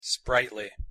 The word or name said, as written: sprightly